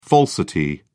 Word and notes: falsity: British English

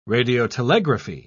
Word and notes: radiotelegraphy: British English

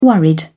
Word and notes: worried: British English

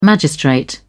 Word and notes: magistrate: British English

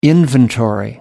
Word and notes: inventory: American English

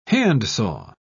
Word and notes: handsaw: British English

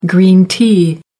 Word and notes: green tea: American English

